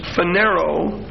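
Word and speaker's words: phaneroo: The Greek word for appear is phanero, same word that's used in 1 Timothy 3:16, that God was manifest in the flesh. P-H-A-N. E-R-O-O. P-H-A-N-E-R-O-O. And that second O is a long O.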